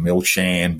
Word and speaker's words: Milchan: But Arnon Milchan, that's A-R-N-O-N, Milchan, M-I-L-C-H-A-N. I think that's the Australian pronunciation of Milchan, by the way. – I imagine so, yeah. – Milchan. I think it's Milchan.